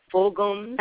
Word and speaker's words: Fulghum: His name is Robert Fulghum, G-H-U-M, Fulghum.